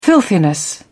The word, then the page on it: filthiness: British English